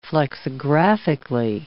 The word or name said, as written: flexographically